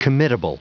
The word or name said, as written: committable